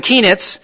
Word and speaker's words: Keenitz: There's the Ingrams, Shirley and Springer, Keltings and Keenitz. Harry himself will tell you that his last name rhymes with peanuts.